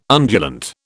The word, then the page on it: undulant: British English